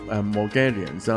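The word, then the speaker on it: Morgellons: 啊，佢外国读音就系 Mongolians 啦，咁咁啊系一种疾病嚟嘅。咁嗱，其实咧要讲讲呢个病先吓，呢种病咧其实喺好耐以前咧曾经咧有一个叫做 Thomas Brown 嘅爵士啦吓，即系讲紧系 1674年嘅时候啊。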